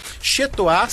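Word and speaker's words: chez toi: Chetoá se escreve C-H-E-Z e Toá se escreve T-O-I.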